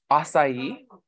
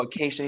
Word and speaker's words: Acacia: he was like, Acai? And I was like, no, Acacia.